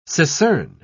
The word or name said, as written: secern